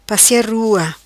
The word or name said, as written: Passia rua